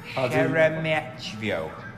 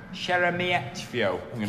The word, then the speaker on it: Sheremetyevo: Sheremetativo. I've pronounced that wrong every time I've said it so far. Sheremetyevo. Sheremetyevo.